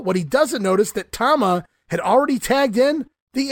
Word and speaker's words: Tama: Tama now in for the heels as well, and he has fun no-selling Jimmy Powers, while Jesse Ventura continues to pronounce the name, it's Toma. As Gorilla finally corrects Jesse on the pronunciation, no, Jess, it's Tama.